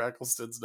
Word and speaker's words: Eccleston: I love the thought of you just looking in your mirror, practicing saying Christopher Eccleston's name.